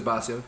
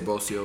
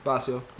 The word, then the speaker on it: Bossio: If you're Hispanic, you say Bossio. – Bossio? – Bossio works. – Which one you rather hear? – Bossio.